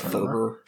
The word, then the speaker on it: fervor: Yeah, just a lot of self-righteousness, and it felt very fanatical, much religious fever. Fever. Yeah. Have you even pronounced that word? That fervor. – Fervor. Yes.